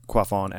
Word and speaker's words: quaff: Quaff is a verb, pronounced K-W-O-F, quaff.